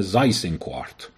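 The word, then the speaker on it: Seyss-Inquart: فرماندار هلند که یک اتریشیول اصله به نام زایسینکوارت یا زایسینکوارت، که جالبه زایسینکوارت ظاهرا من نمیدونم هلندی بلد نیستم ولی با تلفظ هلندی میشه 6 1/4، مثل اینکه برای همین مسخره میکردن میگفتن حالا حکومت افتاده دست 6 1/4.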